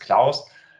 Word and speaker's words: Clous: Äh, wir werden Klaus ausgesprochen das ist der Ingenieur Klaus und die Cloud.